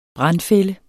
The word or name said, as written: brandfælde